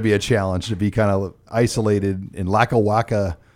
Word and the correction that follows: Lackawanna: Pennsylvania. Did I say that right? – Oh, Lackawanna.